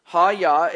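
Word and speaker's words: ha-yah (היה): Sounds like a karate term, but it's ha-yah, and it means I am.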